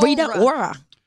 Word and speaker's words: Rita Ora: Rita Ora.